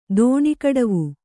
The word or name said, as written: ದೋಣಿಕಡವು